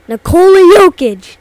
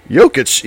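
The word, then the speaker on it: Jokić: Nikola Jokic. – Yeah, nice work. And you even did the soft J. Yeah. Jokic, yes.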